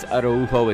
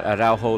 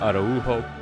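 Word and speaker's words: Araújo: And it is Araujo, it's not Araujo like the Mexican player with the same last name. Uh, this Uruguayan pronounces it Araujo.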